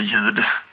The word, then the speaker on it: ljud: Men till exempel så har vi ord som salm till exempel, eller ljud, där vi då stavar LJ på ljud och salmen med P, men vi uttalar inte P, det är liksom stumt.